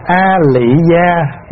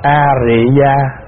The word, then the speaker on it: A Rị Gia: Thưa đại chúng, dù là A Lị Gia hay A Rị Gia đều là chữ phiên âm từ tiếng Phạn là Ariya. Nhưng mà so ra giữa chữ A Lị và A Rị á, thì chữ A Rị nó gần hơn.